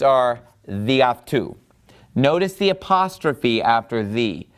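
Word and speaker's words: diaftu (δι' αὐτοῦ): So it's just pronounced diaftu, meaning by him. So all things by him, diaftu.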